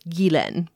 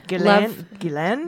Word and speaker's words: Ghislaine: The closest I can suggest is Gilen, where the first syllable is pronounced like the Indian butter, and the second is pronounced, well, like Len. The emphasis is on the second syllable. So Gilen is how you pronounce it. – Pretty. – Gilen? – Gilen.